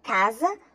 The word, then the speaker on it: casa: Se escreve com S, mas tem som de Z. Opção A, sapo. Opção B, casa.